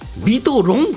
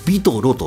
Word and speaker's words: ビトロ: ビトロはね、なんかビトロンってちょっと読んじゃうんだけどね、 VITORON って書いてビトロと読みます。